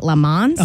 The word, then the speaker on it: Le Mans: Le Mans? – Uh-huh.